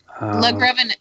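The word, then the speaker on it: LaGravenese: Film that was released in 2013 on HBO, did not get a theatrical release, and was written by Richard Legrevenise? Does that sound right? – Let me see. – Yeah, that's not a usual... – Legrevenise. Yeah, you said it right.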